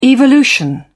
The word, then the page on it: evolution: British English